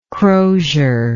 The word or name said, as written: crozier